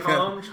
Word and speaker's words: Chum Churum: And the soju is a Korean soju, Churum, is that how you say it? – Chum. – Okay. Wow, I butchered chum.